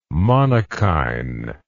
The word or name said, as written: monokine